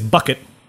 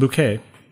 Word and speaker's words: Bucket: Your mom is Bucket. But she pronounces it Bouquet.